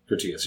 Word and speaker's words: Critias: what is it, Critias? – Critias, yeah. I believe that's how you say it.